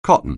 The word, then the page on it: cotton: anglais